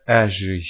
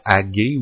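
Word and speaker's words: Aggée: Donc, ça dit, la seconde année du roi Darius, le premier jour du sixième mois, la parole de l'Éternel fut adressée à Agé, ou Agé, là, ça dépend comment vous le prononcez, les deux G. Donc, je devrais dire Agé, peut-être, la spécialiste en français. Deux G, c'est du Agé.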